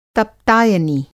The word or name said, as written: तप्तायनी